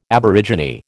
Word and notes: aborigine: American English

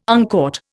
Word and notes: uncaught: Indian

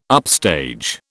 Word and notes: upstage: British English

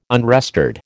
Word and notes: unrestored: American English

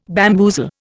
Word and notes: bamboozle: Indian

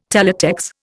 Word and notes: Teletex: Indian

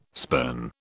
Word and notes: spurn: British English

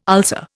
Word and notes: ulcer: Indian

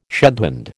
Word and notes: shedhand: American English

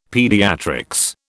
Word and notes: Paediatrics: British English

